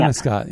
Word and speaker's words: Penobscot: P-E-N-O-B as in boy, S-C-O-T. And they renamed the team after him. – Penobscot, yeah.